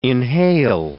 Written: inhale

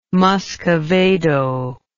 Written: muscavado

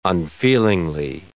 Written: unfeelingly

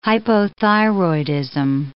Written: hypothyroidism

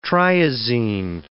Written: triazine

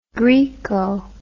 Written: graeco

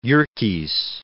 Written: Yerkes

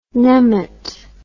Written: nemat

